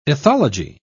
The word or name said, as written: ethology